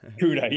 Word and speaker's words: CUDA: Yeah, that's right, CUDA.